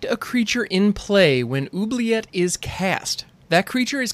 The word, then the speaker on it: Oubliette: And yes, that's how you pronounce that. It's Oubliette. Oubliette. And the only reason that I know how to pronounce that is because of Hoggle from the Labyrinth.